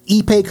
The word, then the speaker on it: Ipe: IP. – No, Ipe. – Ipe? That's how you say it.